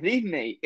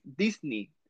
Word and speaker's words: Disney: Y todo el mundo los ve, o sea, todos estamos acostumbrados a pronunciar Nike, pero es Nike. – Nike, ajá. Es Nike, igual Disney. No es Disney, es Disney.